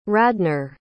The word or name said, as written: radnor